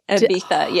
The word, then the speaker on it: Ibiza: Is that how you say it? – Yeah, Ibiza, yeah. And oh Barcelona.